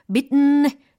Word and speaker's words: beaten: It's off the beaten, beaten 이라고 발음을 하셔도 되지만, beaten, beaten, 네, 비강을 열어주세요.